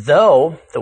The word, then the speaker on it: thought: The O-U-G-H in though, the word in today's text, rhymes with toe. – Add a T to the end of though for thought, and the O-U-G-H sequence now rhymes with awe.